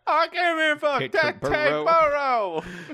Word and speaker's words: Teterboro: Somebody tell me how to pronounce this proper noun. – Proper noun. Teterboro. – Teterboro? – Teterboro. Teterboro. – Teterboro. – Tits or burrow.